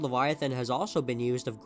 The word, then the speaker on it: Livyatan: This one went under the spelling L I V Y A T A N, and even though it's spelled differently, you still pronounce it like Leviathan.